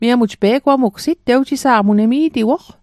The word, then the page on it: pe'gwamugsit: Mi'gmaq